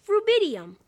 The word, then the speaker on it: rubidium: The next one is ruby. Ruby. – It's pronounced rubidium.